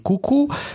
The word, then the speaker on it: Cuckoo: Molto interessante, andatevi a recuperare il trailer di Cucù, che si scrive C-U-C-K-O-O, per trovarlo, se no non...